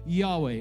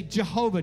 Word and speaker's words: Yahweh: Some would say Jehovah. Jehovah would be kind of the English pronunciation, but Yahweh is how the Jews pronounce it.